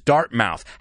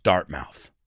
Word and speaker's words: Dartmouth: This is dart mouth. However, it's not pronounced dart mouth. It's pronounced Dartmouth. So that M-O-U-T-H stops being mouth because it's the ending of a word. It's not the word mouth.